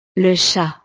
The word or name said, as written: le chat